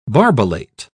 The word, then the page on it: barbellate: British English